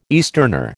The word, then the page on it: easterner: American English